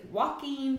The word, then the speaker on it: Joaquin: Next up is Joaquin Phoenix.